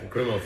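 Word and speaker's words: Krimml: Part of the highlights of that day too, was the Krimmel, I hope I say that right, waterfalls, Krimmel? – Yeah, Krimmel Falls.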